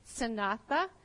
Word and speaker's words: sanatha: The word for tactics is pronounced sanatha, and the word for armor is zaina.